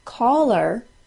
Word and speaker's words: caller: The words caller, C-A-L-L-E-R, and collar, C-O-L-L-A-R. C-A-L-L-E-R is said by most with an A-W sound. Caller. C-O-L-L-A-R is said by most with a short O sound. Collar. I'll say them again in the same order. C-A-L-L-E-R, A-W sound. C-O-L-L-A-R, short O sound.